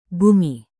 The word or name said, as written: bumi